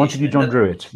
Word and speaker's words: Montague John Druitt: Montague John Druitt, yeah.